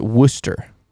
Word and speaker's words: Worcester: So you pronounce it Worcester. Gotcha. Worcester. Got it.